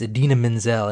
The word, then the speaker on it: Idina Menzel: He comes out to introduce Idina Menzel, and he says, it's pronounced just like it's spelled.